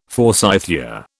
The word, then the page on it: forsythia: British English